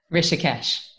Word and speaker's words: Rishikesh: Rishikesh.